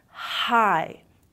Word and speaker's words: high: Quando você veja IGH, IGHT, é aquele long I. High, high.